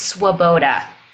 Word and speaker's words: Swoboda: The last name is Swoboda.